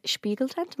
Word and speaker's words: Spiegeltent: In Spiegeltent. Am I saying that right? – Yeah. – Yeah. – Do you know that's German?